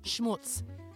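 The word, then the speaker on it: Schmootz: Schmutz. There's no chance I've mispronounced this because it's S-C-H-M-O-O-T-Z.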